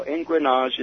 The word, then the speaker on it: engrenage: It's a French term, and it's spelled E-N-G-R-E-N-A-G-E, engrenage.